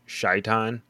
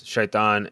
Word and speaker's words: Shaitan: And then another name that they give the dark one in the books is Shaitan, which is Shaitan in literally like spelled the same way in Arabic is the devil. – Is it really? – Yes, Shaitan.